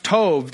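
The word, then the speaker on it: tov (טוב): The Hebrew word for good is a word pronounced tov that indicates that a thing may be pleasing or delightful.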